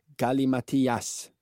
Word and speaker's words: gallimatias: Och det uttalas garanterat inte så. Nej. Som jag gjorde det. – Gallimatias. – Så tror jag exakt, ja, att det är.